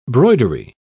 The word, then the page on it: broidery: British English